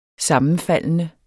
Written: sammenfaldende

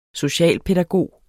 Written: socialpædagog

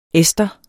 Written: ester

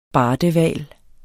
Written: BARDEHVALEN

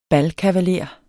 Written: balkavaler

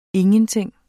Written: ingen

